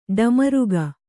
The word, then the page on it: ಡಮರುಕ: ḍamaruka